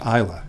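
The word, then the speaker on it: Islay: And he said it's pronounced Isla, it's an Isla malt, and I fell in love with Isla malts.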